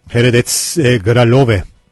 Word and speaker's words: Hradec Králové: Hradec Králové Çekçe nasıl telaffuz ediliyor ona baktım.